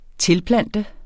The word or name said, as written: tilplante